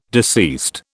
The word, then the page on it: deceased: British English